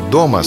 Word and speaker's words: дома: Ударение в слове «дома» следует ставить на первый слог, а не на последний.